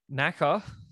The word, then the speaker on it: knacker: So I'm going to say there's no silent K out the front, and I'm just going to say it's N-A-C-K-E-R. – I'm sorry to inform you that there is a silent K. But yeah, knacker is spelled K-N-A-C-K-E-R.